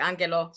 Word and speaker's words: Ange: It's just, that's how you say it in Greek, Angelo.